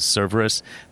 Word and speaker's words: Cerveris: And I have friends who I have known for a long time who will tell people who are pronouncing it correctly as Cerveris that they're like, no, he's a friend of mine. It's Cerveris. Trust me.